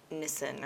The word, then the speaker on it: Nisan: God talks about celebrating Passover on the 14th of Nisan or whatever. Okay. I don't know how you pronounce it. – Yeah, you did it right.